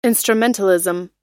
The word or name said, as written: instrumentalism